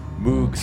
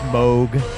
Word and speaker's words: Moog: Moog Sub Fatty.